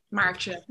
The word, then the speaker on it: Maartje: Yeah, I think this one went a little, like when you say it a bit faster, it works better. Yeah. Maartje.